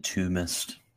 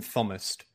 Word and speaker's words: Thomist: Militant Tombist. – I don't know why people keep mispronouncing it. It's the militant Thomist.